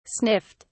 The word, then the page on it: sniffed: British English